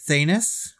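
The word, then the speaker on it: Thanos: And he said, Thanus rhymes with anus.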